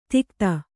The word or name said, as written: ದಿಗ್ಧ